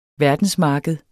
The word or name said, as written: verdensmarked